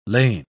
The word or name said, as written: lain